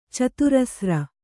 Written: ಚತುರಸ್ರ

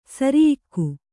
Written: ಸೆರೆಯಾಗು

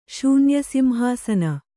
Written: ಶೂನ್ಯಸಿಂಹಾಸನ

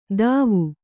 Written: ಥಾವು

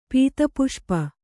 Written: ಪೀತಪುಷ್ಪ

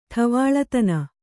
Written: ಠವಾಳತನ

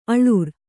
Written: ಅಳುರ್

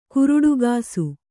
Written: ಕುರುಡುಗಾಸು

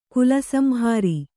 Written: ಕಾಲಸಂಹರ

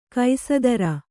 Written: ಕಸದಾರ

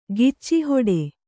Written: ಗಿಚ್ಚಿಹೊಡೆ

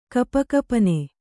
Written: ಗಪಗಪನೆ

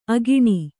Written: ಅಕ್ಕಣಿ

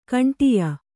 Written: ಖಂಡೆಯ

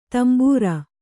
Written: ದಾಂಬರು

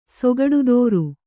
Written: ಸೊಗಡುದೋರು

